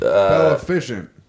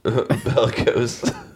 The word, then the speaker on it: Bellicose: Bell-efficient. – Bellicose.